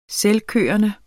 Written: selvkørende

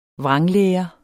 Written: vranglære

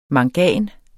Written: mangan

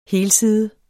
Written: helside

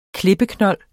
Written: klippeknold

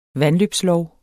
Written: vandløbslov

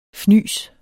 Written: fnys

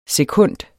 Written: SEKUNDS

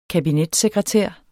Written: kabinetssekretær

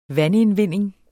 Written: vandindvinding

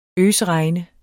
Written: øsregne

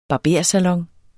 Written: barbersalon

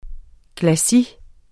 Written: Glacis

